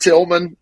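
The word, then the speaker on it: Tilghman: Cause it's just Tillman, but it's got that GH right in the middle, which is, I don't know.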